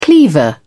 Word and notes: cleaver: British English

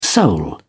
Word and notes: soul: British English

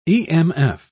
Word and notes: emf: British English

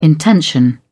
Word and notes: intention: British English

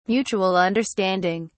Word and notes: mutual understanding: British English

